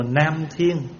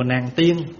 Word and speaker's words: Nam Thiên: Hồi nãy mình đi vào chùa Nam Thiên á, chùa đó tên là chùa Nam Thiên, mà người tiếng Đài Loan người ta phát âm là Nạn Thiên, chứ không phải là chùa Nàng Tiên.